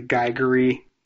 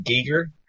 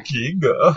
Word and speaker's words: Giger: It's all very Geiger-y, if that's how you mm-hmm. pronounce his name. – It's actually pronounced Giger. – Okay. – Giger.